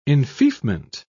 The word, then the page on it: enfeoffment: British English